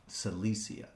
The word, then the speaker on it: Cilicia: Cilicia is the way you pronounce that.